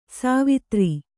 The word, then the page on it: ಸವಿತಾರ: savitāra